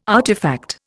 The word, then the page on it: artifact: Indian